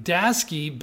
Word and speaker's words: Daseke: Daseke bets heavy on oil with the Aveda acquisition.